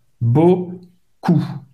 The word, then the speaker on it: beaucoup: Beau, beaucoup. O, U em francês tem o som da letra U em portugais. ok? Cou. O P no final et mudo, Non vai ser pronunciado.